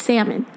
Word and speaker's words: salmon: because he didn't know how to pronounce salmon. And he said, is it seared salmon or salmon? And Derek's like, bro, it's salmon.